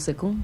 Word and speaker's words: Sukun: Y pues nosotros decimos Sukun como tío, no sé ustedes cómo lo manejan. – También le decimos Sukun.